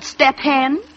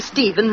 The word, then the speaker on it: Stephen: and I read the name Stephen wrong. I used to read it S-T-E-P, step, H-E-N, hen. Step hen is Stephen. Stephen is step hen. – I, step hen. – S-T-E-P, step, H-E-N, hen.